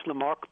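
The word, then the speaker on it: Lamarque: Good morning, and you pronounced Lamarck perfectly.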